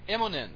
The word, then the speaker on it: Immonen: How do you say it last Immonen. – Name? Immonen. Stuart Immonen.